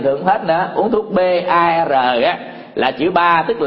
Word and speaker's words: BAR: tức là chữ B bò ha, chữ A này nè, A lớn này với chữ R cái rổ á, BAR đọc là chữ ba, chữ ba đó tiếng Anh đó là cái thanh xà đó, cái xà cái cây rồi đó là ba,